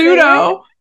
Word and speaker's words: pseudo: Is that not how you say pseudo. – It? – The P is silent. And you didn't even put a P in it.